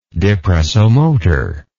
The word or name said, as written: depressomotor